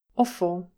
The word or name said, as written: offal